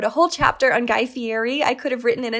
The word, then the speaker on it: Fieri: And so Guy Fieri, I think he pronounces his name Fieri, was on that second season in 2006, where people are competing for their opportunity to have a show on Food Network.